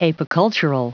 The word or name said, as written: apicultural